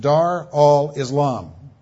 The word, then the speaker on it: Dar al-Islam: In Arabic, it's pronounced Dar al-Islam, house of peace, and Dar al-Harb, war.